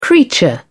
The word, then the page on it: creature: British English